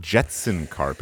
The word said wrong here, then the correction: Jensen Karp: It's Jensen Karp, which I already said that.